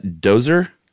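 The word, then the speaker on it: Djoser: And this is around 2600 BC or 2600 during the time of King, is that Dozer? – That's how I take it. – J is silent, right? – Dehoser.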